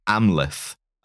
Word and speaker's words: Amleth: This is Amleth, the pronunciation TH. This is Hamlet.